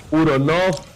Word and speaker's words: Uronov: Final, si ustedes miran los nombres, una V al final que se pronuncia como F. Es decir, Uronov, Saifijet, ¿cierto? Todos tienen esa F, esa F al final.